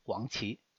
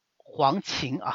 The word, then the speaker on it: 黄芩: 这样的方子还挺多的，比如说非常有名的一味清金散，那就是黄芪啊。黄芪呢，黄芩啊，不是黄芪啊，我发音可能不是特别的准啊，就是三黄：黄连、黄柏、黄芪。